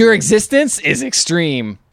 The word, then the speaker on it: eXistenZ: It's spelled, it's said eXistenZ, but it's spelled E-X. X is very big. It's like a capital X, I-S-T-E-N, and capital Z.